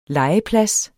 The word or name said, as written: legeplads